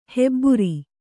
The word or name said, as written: ಹೆಬಾರು